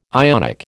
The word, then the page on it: Ionic: American English